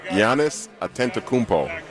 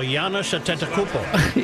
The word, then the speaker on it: Giannis Antetokounmpo: Yes, Giannis Antetokounmpo. – Oh, Giannis Antetokounmpo.